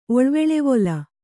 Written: ಒಳ್ವೆಳೆವೊಲ

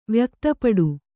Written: ವ್ಯಕ್ತಪಡು

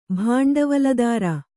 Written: ಭಾಂಡವಲದಾರ

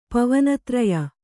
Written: ಪವನತ್ರಯ

